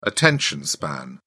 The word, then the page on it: attention span: British English